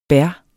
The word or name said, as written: bær